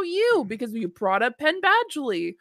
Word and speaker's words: Penn Badgley: It always comes back to fucking Penn Badgley, whatever the fuck his name is.